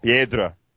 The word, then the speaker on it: Piedra: Me and Piedra, excuse me, let me get the name right, Piedra, because it is Spanish.